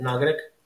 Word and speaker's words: Naugatuck: Now they pronounce that Naugatuck. – Naugatuck. – Naugatuck, but everybody from Naugatuck calls it Naugatuck.